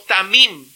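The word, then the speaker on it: tamim (תמים): Y la palabra hebrea aquí se pronunciaría más o menos como tamim. Y ese perfecto significa sin defecto, significa íntegro y significa entero, que no le falta nada.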